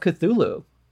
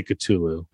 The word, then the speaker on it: Cthulhu: I always say Cthulhu, which I'm probably saying it wrong. – Well, you know, there's no H in it. And there, yeah, and it's with a K.